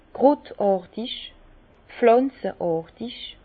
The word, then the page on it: krutàrtig, pflànzeàrtig: Bas Rhin; Herrlisheim